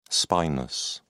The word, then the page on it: spinous: British English